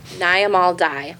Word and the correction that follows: Nyamal Day: Day, actually. I apologize. That's, you say her name, Nyamal Day.